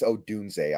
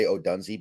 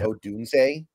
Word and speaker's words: Odunze: Oh, by the way, it's Odunze. I always say Odunze because that's how it's spelled, but it's Odunze.